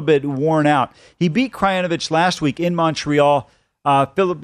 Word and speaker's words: Krajinovic: Krajinovic.